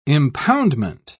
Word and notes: impoundment: British English

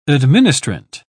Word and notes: administrant: British English

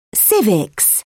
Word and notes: civics: British English

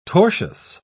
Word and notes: tortious: British English

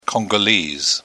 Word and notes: congolese: British English

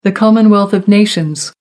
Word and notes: commonwealth of nations: American English

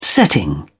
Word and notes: setting: British English

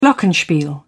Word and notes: glockenspiel: British English

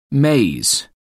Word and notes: maize: American English

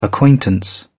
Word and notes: acquaintance: British English

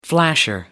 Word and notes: flasher: American English

